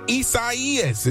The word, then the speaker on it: Isaias: Hurricane Isaias, did I say it right? Yep.